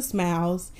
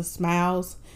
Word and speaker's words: Smilez: Before I end this podcast, if you'd like to follow me on social media, my Instagram name is Lisa Smiles, and Smiles is spelled with a Z at the end, Z as in zebra. I'm also on Facebook, Styles by Lisa Smiles.